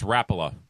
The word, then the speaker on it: Rapala: So last week, I mispronounced the pronunciation. That's the only thing you can mispronounce of Rapala? Rapala? – It's Rapala.